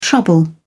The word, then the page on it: trouble: British English